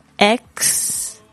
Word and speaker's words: exercise: 注意一下这个它的这个发音哦，它是 exercise，特别注意一下那个声音，那个 X 的声音， exercise，运动，多多运动对身体好，然后也会让你觉得更开心，因为好像就是运动的时候你会脑子里会分泌一个化学物质，这个应该叫叫什么忘记了，分多金还是什么东西的，然后呢就会让你觉得更开心。